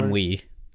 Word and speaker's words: ennui: the constant sense of isolation and inui and... Is it inui? Inui? How do you say that? Ennui. – Ennui. I wasn't even close.